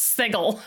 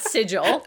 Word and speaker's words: sigil: So the majesty said, it's pronounced sigil. I was like, okay. – Sigil, not sigil.